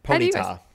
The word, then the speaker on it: Ponyta: Ponyta. – The emphasis is on the ta.